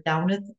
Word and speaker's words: Damhnait: It is by author Downeth Monahan. Her name is spelled D-A-M-H-N-A-I-T, Monahan, M-O-N-A-G-H-A-N.